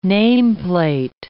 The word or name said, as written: nameplate